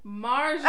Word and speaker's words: Marjorie: That's not her name. – Marjorie. – Oh, it's not Majorie? – Right! It's spelled like a Majorie. It's spelled Majorie. It is spelled Majorie. – It's Marjorie. – It's supposed to be Marjorie. – It's Marjorie. – It got an R in between the A's.